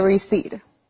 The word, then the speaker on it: receipt: It is spelled R-E-C-E-I-P-T. Remember that we don't say the P.